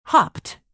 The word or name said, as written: hopped